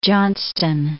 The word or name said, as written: Johnston